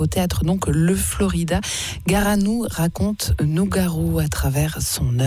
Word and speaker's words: Garanou: On peut retrouver plein d'infos en tapotant Le Florida dans un moteur de recherche et puis on peut vous écouter Garanou aussi, Garanou en un seul mot et sans S à la fin et sans E entre le gar et le A. Garanou, comme ça se prononce, Garanou raconte Nougaro, donc avec vous, Serge Mathéon.